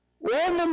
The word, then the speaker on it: ओम: ओम में जो ओ है वो व्याकरण के नियम के अनुसार उसमें उसको लंबा बोलना होता है, उसको कहते हैं प्लुत उच्चारण। प्लुत का मतलब अ को बोलने में जितना टाइम उसमें तीन गुना टाइम लगाना।